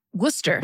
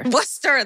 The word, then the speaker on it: Worcester: What, how do you say it? – Worcester. – Worcester, that's it.